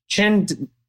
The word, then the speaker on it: Qin: So, but the Qin Qin, is that right? – Yeah, you really have a knack for Chinese. Your pronunciation, it's just perfect.